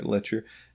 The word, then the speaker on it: Letscher: Yeah, and the actor's name is Matt Letcher. Mm. It's spelled weird, L-E-T-S-C-H-E-R, Letcher, and has not really done a whole lot.